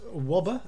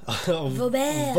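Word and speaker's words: Wöber: How is it pronounced? Wobber? – Vauber.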